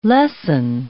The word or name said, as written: lessen